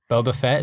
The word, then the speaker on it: Boba Fett: Oh, Boba Fett. I love him. – Boba Fett, is that what you said? – Yeah. – Yeah, you got it.